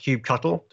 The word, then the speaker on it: kubectl: Other people pronounce it different ways. It's officially pronounced kubectl. Don't let anyone tell you different.